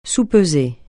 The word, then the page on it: soupeser: francais